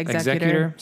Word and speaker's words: executor: I don't know how to say this word. Executor? – Yeah, executor.